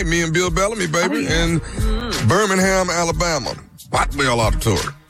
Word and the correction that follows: Boutwell: Am I saying that right? Boutwell or Botwell? Boutwell.